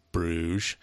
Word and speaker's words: Bruges: So Bruges, pronounced Bruges, the most well-preserved medieval city in the whole of Belgium, is a welcoming destination for travelers from all over the world.